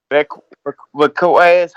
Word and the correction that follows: Valdez: how you say his name? – Valdez? – Valdez, yeah. I hope Valdez get upset tonight since he won't cheat with that magic potion and stuff like that.